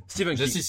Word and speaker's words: Stephen King: Stephen King. sur Stephen. Oui c'est vrai. Faut le prononcer à l'américaine.